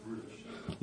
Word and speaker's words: brutish: But he that hateth reproof is brutish. – Let's start with that last word. It's not British, okay? It's brutish. Alright?